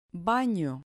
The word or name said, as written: bano